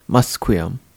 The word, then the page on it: Musqueam: English